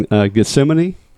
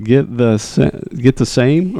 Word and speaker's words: Gethsemane: But it's from a story called Logstill Distillery in Gethsemane, Kentucky. – Now, I like that pronunciation than what I tried to call it. – You were like, get the same or something. Get the main.